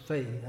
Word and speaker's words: Fahy: Is that how you pronounce his name? F-A-H-Y? Fahy. I think so, because if you remember, there was an American singer called John Fahy, but it was spelled F-A-H-E-Y.